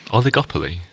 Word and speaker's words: oligopoly: Oligopoly.